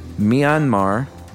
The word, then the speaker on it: Myanmar: About 3 weeks ago, I was flying from Minnesota to Yangon, Myanmar. Is that how you pronounce it? – Yeah, it's Burma. – Myanmar? – But it's post-junta Burma.